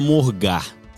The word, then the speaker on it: morgar: Nesse exato momento, 11 horas, tô começando a morgar. – Ah, ah morgar, morgar é, é legal. Só que eu puxo o R, né? Morgar. Eu puxo ah, o R. Ah, morgar.